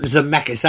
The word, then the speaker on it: Zemeckis: Zemeckis? – Robert Zemeckis. – Zemeckis, that's how you pronounce the last name.